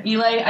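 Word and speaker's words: Islay: Is it Ela? Am I saying it right? – Ely.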